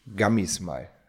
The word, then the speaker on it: Gummy Smile: nämlich Gummy Smile. Habe ich es richtig ausgesprochen? – Du hast es perfekt ausgesprochen. Ich hätte es nicht schöner sagen können.